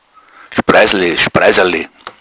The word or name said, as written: spreissl